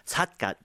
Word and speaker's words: Satgat: So just to give a bit more background to this poet, Kim Sagat, or Satgat is actually how you say it in Korean, was born at the beginning of the 19th century in 1807.